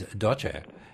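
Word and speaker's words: doche (多謝): So even quite differently, like thank you in Cantonese is doche, and in Mandarin it's sheshe, written the same.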